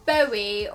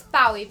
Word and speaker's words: Bowie: Is it Bowie or Bowie? I think it's Bowie because he called his son Zowie Bowie. – Yeah. – So that makes sense to me. R.I.P. David Bowie.